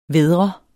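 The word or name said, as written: VÆDRE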